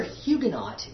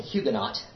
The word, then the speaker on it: Huguenot: In French, it's Huguenot. And the H is silent and the T is silent.